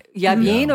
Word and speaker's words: Yavin: Yavin, or how do I pronounce Yavin.